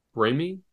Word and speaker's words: Raimi: How do you pronounce his name? Raimi? – Raimi? – Raimi. – I was saying Rami my whole time.